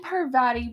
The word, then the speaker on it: Parvati: So Lavender and Party... I really butchered that name, did I not? Lavender and Parvati both giggled and looked at Harry, which, like, I think Harry, that would make me really uncomfortable.